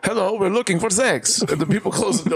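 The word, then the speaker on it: sechs: And they're going, they say, you say, how you say six in German, it's pronounced sex.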